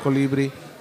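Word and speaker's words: Colibrí: La canción del el canto del colibri. Colibri. I have to say it right. April yelled at me because I said the wrong fucking accent. – Really? You gotta say it all Spaniard.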